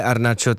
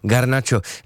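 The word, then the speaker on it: Garnacho: Tak to je jenom takový šotek, co se ano, a taky vlastně, kdybyste někdy slyšeli, že ho někdo vyslovuje Arnáčo, tak on sám se takhle představuje, ale vzhledem k tomu, aby byli diváci, fanoušci a posluchači spokojení, tak ho říkáme Garnáčo.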